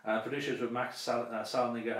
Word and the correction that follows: Saldinger: and Atsumi Yajima. Saldinger. – Oh, Saldinger, okay. That is not what you said.